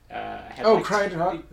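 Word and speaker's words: Cyttorak: Sidorak, or how do you pronounce it? – Crytok.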